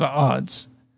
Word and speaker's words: Adz: I brought Sufjan Stevens, The Age of Odds. It's spelled ADZ, but it's pronounced Odds.